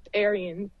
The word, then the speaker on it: Ahrens: It's Ahrens. – Oh, so the E is pronounced. – Yes. – Ahrens. – Believe it or not, it is.